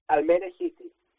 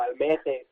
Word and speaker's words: Almere: Bueno, claro, ellos lo pronunciarán distinto un poco, Almeje, creo, un poco como Jota o no sé.